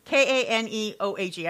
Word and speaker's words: Kaneohe: Kanoehe? Kaneohe. I know they pronounce every syllable, and I don't know, you know.